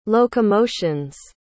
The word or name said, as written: locomotions